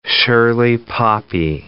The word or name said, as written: Shirley poppy